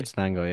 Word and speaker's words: Acatenango: I think you did the same for Atitinango. Is that how you pronounce it? – Acatenango, yeah.